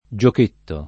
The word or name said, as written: giochetto